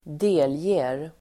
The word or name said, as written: delger